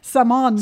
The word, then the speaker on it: salmon: Because I'm like, there's no L in salmon. – Hey, you don't spell it S-A-M-O-N. – Salmon.